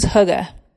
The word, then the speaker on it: hygge: Hobbits are all things hygge. Hygge is a Danish and Norwegian word spelled H-Y-G-G-E, but it's pronounced hygge.